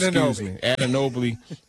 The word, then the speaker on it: Anunoby: I can never pronounce his name. – Anunoby. – I was going to put that L in there for some reason.